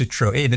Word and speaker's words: Citroën: But yes, pronounced Citroën.